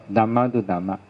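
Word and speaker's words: Dhammānudhamma: 所以连起来读作 Dhamma Du Dhamma， Anu Dhamma， Anu 在这边是跟随，跟随 Dhamma，跟随依照法，依是就是依照法，依照法 Patipatti 是修行，依照法修行来体证九个初世间法，所以整个念成是 Dhamma Du Dhamma Patipatti。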